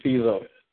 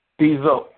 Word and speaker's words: Stezo: My name's Steezo. – You said Steelo? – Steezo. – Okay, spell it for me, brother. – S-T-E-Z-O.